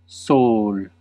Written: soleil

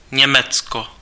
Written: Duitsland

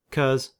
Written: 'cause

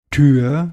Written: door

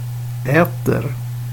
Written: äter

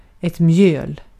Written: mjöl

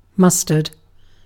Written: mustard

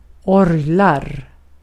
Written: orglar

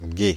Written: gué

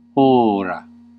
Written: o'clock

